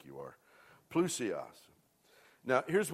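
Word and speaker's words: plousios (πλούσιος): Here, the word is that's used here is pronounced plousios. Let's say that. Plousios. You know, I hope you're saving these gems on these bulletins, man.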